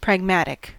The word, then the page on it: pragmatic: American English